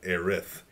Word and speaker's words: Aerith: They're pronouncing it Aerith. Which is correct. – Which is correct, like, Japanese, in the Japanese culture.